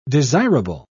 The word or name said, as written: desirable